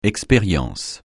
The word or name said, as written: expérience